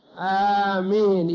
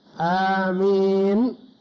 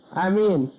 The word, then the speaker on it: آمين: يقول: وفي آمين لغتان، المد على وزن فاعل، آمين يعني مد الهمزة آمين، والقصر على وزن يمين أمين بدون مد الهمزة يصح. قال الجوهري رحمه الله: وتشديد الميم خطأ، بعض الناس ينطق بها آمين، يشدد الميم فيحيل المعنى، لأن آمين اللهم استجب، وآمين بمعنى قاصدين، وهذه لا معنى لها هنا، فتشديد الميم خطأ كما ينطق بها بعض الأشخاص.